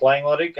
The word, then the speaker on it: Langeluttig: Yeah, my name is Mike Langeluttig, and I'm from Lufkin.